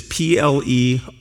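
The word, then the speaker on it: pleroo (πληρόω): And the other one is plerao, which is pleroo.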